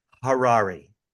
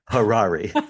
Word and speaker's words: Harari: By the way, it's Harari. – Harari. – Harari. That's how I remember. It's like rhymes with Ferrari, and that's how I remember it. But Dan Harari, just for the record.